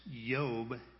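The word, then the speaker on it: Yob (איוב): Job is pronounced Yob in Hebrew.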